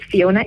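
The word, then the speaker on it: Phiona: That's spelled with a P-H-I-O-N-A. Or on Twitter, it's Miss Fiona, M-S-P-H-I-O-N-A.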